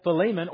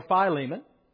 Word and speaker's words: Philemon: In fact, not only Colossians, but Philippians, Ephesians, and Philemon or Philemon, both pronunciations, well accepted, if you like.